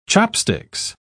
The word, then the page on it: chopsticks: British English